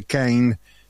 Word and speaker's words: Kind: Se pronuncia Kind y no Kind.